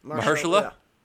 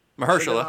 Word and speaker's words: Mahershala: I pronounce his name. Mashallah. – Mahershala. – Mahershala. Ali. Okay.